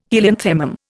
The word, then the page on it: helianthemum: Indian